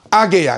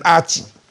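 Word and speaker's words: 阿給: 所以啊，我们看看侯友谊批赖清德对八八枪击案晋升，然后有人反酸说阿给啊，你都念错，念成阿吉。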